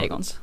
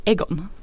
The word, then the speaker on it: Aegon: Det finns flera jag, Aegons. Ja, tack. Och det är kul att de inte uttalar Aegon utan då säger Egon.